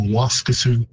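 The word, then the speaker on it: Waskesiu: So Waskesu, it would be a longer vowel in Cree, even though the stress is on the first syllable. Waskesu.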